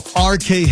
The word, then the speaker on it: Arch: RK Health, a primary care clinic. If you're looking for it online, it's spelled A-R-C-H.